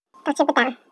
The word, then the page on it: tuuhchipitaau: Chisasibi